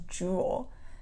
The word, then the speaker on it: Jewel: It's Jewel. It's not until I spell it that people are like, oh, like jewelry.